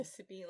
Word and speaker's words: Sippy: Sippy, like a sippy cup? – Yeah, okay.